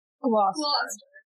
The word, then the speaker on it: Gloucester: Gloucester.